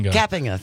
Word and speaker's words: Kapinga: because we've been saying Kapinga. – Kapinga, and which is not it. – No one has corrected us. – Yeah, that's not it. It's Kapinga. – Kapinga, yeah.